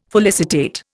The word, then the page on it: felicitate: Indian